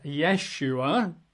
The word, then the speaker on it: Yeshua: You're probably familiar with one of the most popular is Yeshua, often Y apostrophe S-H-U-A, Yeshua. Sometimes there's an E in there, Yeshua. So Y-E-S-H-U-A, Yeshua. Got to have the Yeshua, not Yeshua.